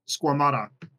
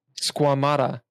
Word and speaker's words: Squamata: Squamata. – Squamata.